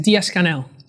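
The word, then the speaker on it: Díaz-Canel: But President Diaz, how do you pronounce his last name? – Diaz-Canel.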